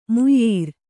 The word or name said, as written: ಮುಯ್ಯೀರ್